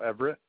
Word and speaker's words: Everett: Michael Everett. – Can you spell that last name? – E-V-E-R-E-T-T.